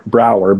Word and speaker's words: Brouwer: So you asked how to pronounce my name earlier, Brower, B-R-O-U-W-E-R, and people ask, what's wrong with the spelling?